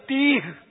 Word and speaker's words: تیہ: میدان تیا میں تیا تیا نہیں تیا، وہ اصل تلفظ ہے تیہ، تیا نہیں ہے تیہ، یہ یاد کر لیں.